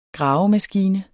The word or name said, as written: gravemaskine